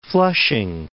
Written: Flushing

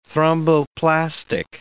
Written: thromboplastic

